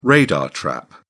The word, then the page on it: radar trap: British English